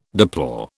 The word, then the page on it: deplore: British English